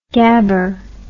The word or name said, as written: gabber